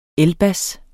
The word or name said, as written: elbas